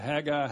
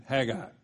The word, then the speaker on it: Haggai: And by the way, there are about three or four different ways to pronounce that. Haggai, Haggai, I've heard. So you're just going to have to stick with the Northwest Florida pronunciation. Haggai reveals a collaboration of the prophetic, the priestly, and the political leadership.